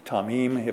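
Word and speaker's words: tamim (תמים): The Hebrew for perfect is tamim. We would spell it T-A-M-I-M, tamim, if we'd spell it that way. Pronounced T-A-W-M-E-E-M, tamim.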